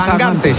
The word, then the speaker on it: Sangante: Se pronuncia sangante. – Yo pronuncio como me digáis. – Bueno, eso lo he oído yo, que dice que es sangante. – De hecho, me ha dicho, tú acuérdate de mangante.